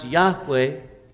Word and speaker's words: Yahweh: They take the consonants from the word that God revealed as his name to Moses at the burning bush, that we pronounce Yahweh.